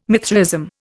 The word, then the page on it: Mithraism: Indian